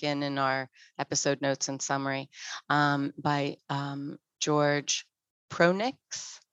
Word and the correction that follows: Prochnik: Prochnik, I believe is how you pronounce it.